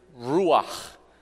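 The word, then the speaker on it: ruach (רוח): And similarly, in Hebrew, the word for spirit is ruach.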